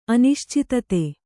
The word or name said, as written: ಅನಿಶ್ಚಿತತೆ